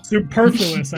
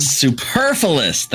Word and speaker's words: superfluous: Superfluous, I think you mean. – Superfluous. That is correct.